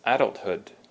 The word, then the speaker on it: adulthood: So our first word is adulthood. Adulthood, with the emphasis on the first syllable, the ad.